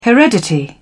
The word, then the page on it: heredity: British English